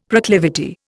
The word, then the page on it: proclivity: Indian